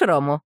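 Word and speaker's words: Rome: «Хочешь рому?» — лукаво улыбаясь, спрашивает она. «Нет, считаю должным отдать кесарю кесарева». – Рим по-французски «ром» произносится как русское слово «ром».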